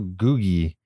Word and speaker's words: Googie: Come back, little Googie. That's how you pronounce it.